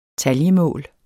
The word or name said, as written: taljemål